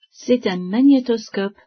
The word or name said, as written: magnétoscope